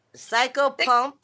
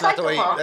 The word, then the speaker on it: psychopomp: You gotta say psychopomp. – No, yeah, that's psychopomp.